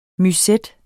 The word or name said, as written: musette